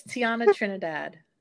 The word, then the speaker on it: Tierna Trinidad: Can you tell us your name properly? Because I think I butchered it right there. – Oh, that's okay. It's Tierna Trinidad. – Okay. Trinidad, like the country? – Yes.